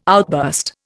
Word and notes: outburst: Indian

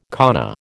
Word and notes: kana: American English

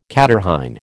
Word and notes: catarrhine: American English

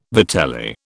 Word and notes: vitelli: British English

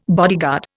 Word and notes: bodyguard: Indian

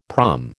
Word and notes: prom: American English

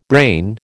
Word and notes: brain: American English

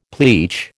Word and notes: pleach: American English

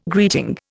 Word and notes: greeting: Indian

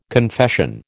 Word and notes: confession: American English

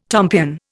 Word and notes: tompion: Indian

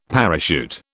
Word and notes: parachute: British English

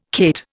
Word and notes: cate: Indian

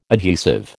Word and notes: adhesive: American English

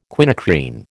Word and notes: quinacrine: American English

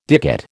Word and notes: dicot: American English

